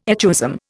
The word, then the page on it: echoism: Indian